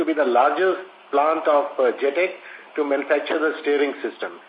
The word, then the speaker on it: JTEKT: Now let me clarify, like, it is not JTEKT, it is JTEK. The word is, it is single word which is pronounced as JTEK.